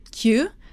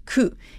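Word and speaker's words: ku (九): You might have heard yong and kyu, but there's also pronunciation shi and ku. And shi sounds like the same as death, and ku from that's something that worries you, that troubles you, that makes you feel pain and sorrow.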